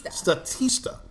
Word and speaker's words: Statista: So bottom line is, according to a 2018 report, am I saying this right? Statista. Statista. – Yes. – Like Batista, uh-huh. Only with stats.